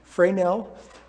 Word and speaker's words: Fresnel: In 1822, a French scientist, Augustin Jean Fresnel, it's spelled Fresnel, but I'm trying to learn a little bit of French here, Fresnel, was studying optics and light waves.